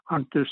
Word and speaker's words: Hunter: Okay, Henry Hunter Sr. And spell it for me, Henry. – H-E-N-R-Y, Hunter, H-U-N-T-E-R, Sr., S-R.